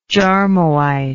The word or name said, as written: jarmoite